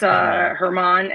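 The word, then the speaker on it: Germán: You're looking at Cole, Cortez, Montas, Talion, Herman, German. How do you pronounce it? – It's Herman, and it's Tyone, Jameson Tyone, like a tie that you own.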